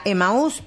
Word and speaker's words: Emaús: Tiene tilde en la U. – Emaús, por eso he dicho, ¿no? Digo, si tiene tilde, se dirá Emaús, era una figura bíblica.